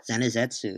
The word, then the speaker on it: Zenitsu: I'm really bad at pronouncing names, but I would say Zenitsu, isn't it? – Zenizetsu, yes.